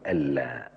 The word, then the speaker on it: ألا: نسمع الحديث مرة تانية ونقول: ألا، هي لما بتتكتب بتتكتب أن لا، زي أشهد أن لا إله إلا الله تتكتب أن لا، لكن النون ما تنطقش، لذلك أنا حاطط لك عليها سكون وحاطط شد على اللام، يعني النون لا تنطق لولا ألا.